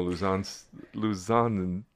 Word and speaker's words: Homo luzonensis: Homo luzonensis. See, I had it. Luzonensis with the emphasis on the second syllable.